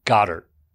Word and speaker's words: Goedert: You called the Eagles player Dallas Goedert. – Yeah, yeah. No, his name is pronounced Goddard. – Yeah, as in God.